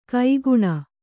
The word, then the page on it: ಕೈಗುಣ: kaiguṇa